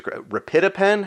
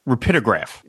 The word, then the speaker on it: Rapidograph: You always rapidapen. How do you say that? – Rapidograph.